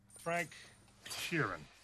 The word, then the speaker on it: Frank Sheeran: Frank Sheeran.